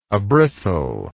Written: abristle

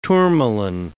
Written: tourmaline

